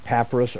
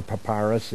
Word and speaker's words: papyrus: Page 28, I want you to be aware of papyrus or papyrus. It can be pronounced either way.